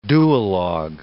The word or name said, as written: duologue